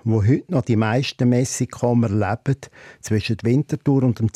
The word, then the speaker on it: Messikommer: Der Familienname Messikomer wird in der Mundart vom Zürich Oberland Witz erwartet als Messikomer ausgesprochen.